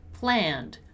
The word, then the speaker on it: planned: First of them is the pronunciation of the word planned. Planned. Notice how we don't pronounce the letter E, right? So planned.